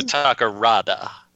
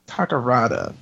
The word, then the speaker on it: Takarada: Let's talk about Akira Takaruda then a little bit. – Let's say his name correctly, though. Akira Takarada. – Takarada.